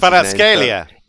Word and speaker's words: Kvaratskhelia: Kvaratskhelia.